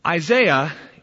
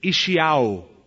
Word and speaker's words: Isaiah: Isaiah is our American pronunciation, and it's actually the wrong pronunciation. His name should be pronounced in its Hebrew, Ishiyahu.